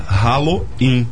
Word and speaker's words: Halloween: Para celebrar o Halloween, o Bartelier Gastro promove sua primeira Halloween. Não errei na pronúncia, não. É com R-A-L-O-I-N mesmo.